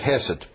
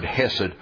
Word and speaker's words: hesed: You remember we said the most important word in the New Testament, hesed, C-H-E-S-E-D or H-E-S-E-D. It all depends on whether I'm feeling American or German as to how I pronounce that word.